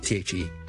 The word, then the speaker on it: the: 今日有听众问 THE 呢个 definite article THE the 啦 the 前面咧系一个 th 音 voice 嘅 the。 Okay, 但是很多时候呢都会读成有啲似一个 voice plosive 嘅字嘅 the。